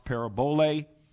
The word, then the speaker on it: parabole: And the word figure there is the word parable, or it's pronounced parabole in the Greek, and it means a similitude, a parable.